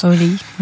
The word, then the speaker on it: Hawley: Hawley. Je sais jamais trop comment on prononce. – Holly, moi je dis.